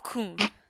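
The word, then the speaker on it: Cosne: When they got close, Virginia relocated to the town of Kuhn in May 1944. I would have said that one wrong, because there's an S in there, and you don't pronounce that S. The town of Kuhn in May 1944.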